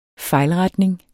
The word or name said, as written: fejlretning